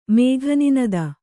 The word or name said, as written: ಮೇಘನಿನದ